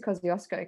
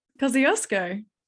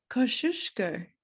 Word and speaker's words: Kosciuszko: Kosciuszko. – Yeah, how do you say it? – Kosciuszko.